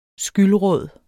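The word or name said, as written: skyldråd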